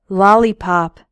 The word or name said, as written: lollipop